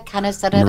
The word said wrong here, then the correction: Kanakaredes: Maria Kanakaredes, yes.